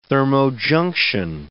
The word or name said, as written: thermojunction